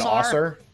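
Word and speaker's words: Ausar: How do you pronounce the two Thompson's first names? Amen and Osser.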